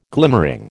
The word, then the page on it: glimmering: American English